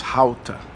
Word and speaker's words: Gouda: She taught me that in Dutch, Gouda is actually pronounced Houta.